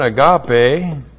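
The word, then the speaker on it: agape (ἀγάπη): The verb is A-G-A-P-A long O, agapao. From the verb, we get the noun agape. A-G-A-P long E, and both of them are words that describe and deal with virtue love.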